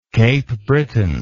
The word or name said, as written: Cape Breton